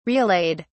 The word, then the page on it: relayed: British English